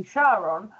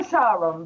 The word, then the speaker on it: Charon: It's actually disputed because it depends on the spelling. Sometimes it's spelled with a H, where it would be Charon, and sometimes it's spelled with a K, where it would be Charon.